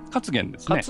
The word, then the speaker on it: 勝元: はい、飲み物のね名前をつけてる方で、うん、発音は勝元なんですか。 – 勝元ですね。